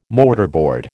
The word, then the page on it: mortarboard: American English